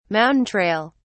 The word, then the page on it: mountain trail: British English